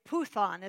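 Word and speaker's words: puthon (πύθων): It's puthon, it's pronounced.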